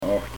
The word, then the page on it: acht: Pennsylvania Dutch